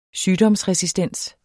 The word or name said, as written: sygdomsresistens